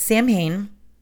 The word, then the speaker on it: Samhain: Samhain, and some people pronounce it Samhain, is also known as Halloween.